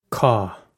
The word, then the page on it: cá: Irish